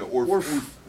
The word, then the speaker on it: Ourph: And they always had one mingle with them called Oomph. Oomph? – Or O-U-R-P-H. – We pronounce everything different. – Yeah, Oomph.